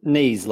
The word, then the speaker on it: Nies: Nies, like the bit on your legs.